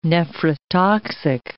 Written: nephrotoxic

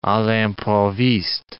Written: a l'improviste

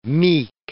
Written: meek

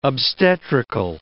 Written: obstetrical

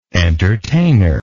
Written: entertainer

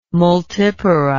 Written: multipara

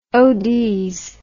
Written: ods

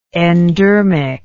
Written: endermic

